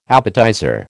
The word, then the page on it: appetizer: American English